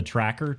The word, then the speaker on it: Trakr: it's called the tracker t-r-a-k-r okay i think i'm saying that right you um, are yes